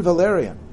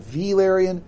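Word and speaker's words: Velaryon: These are Valerion versus Valerion. – Pronounced the exact same, just because George loves that.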